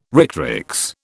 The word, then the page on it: rectrix: British English